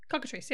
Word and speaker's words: cockatrice: Tom wants to know what is the best way to cook, am I saying this right, cockatrice? – Cockatrice, yes.